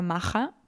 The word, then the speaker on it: Maja: Pero yo creo que se pronuncia Maja porque ella es alemana e italiana, ¿vale?